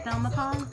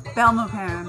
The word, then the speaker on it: Belmopan: Belmopan? – Belmopan.